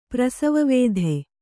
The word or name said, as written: ಪ್ರಸವವೇಧೆ